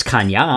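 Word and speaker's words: Caña: Unless with that little thing above it, it's Cana.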